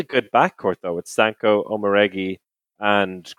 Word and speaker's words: Omoregie: I just love how you you you, you kind of ducked out of the end of the pronunciation, like Omoregie.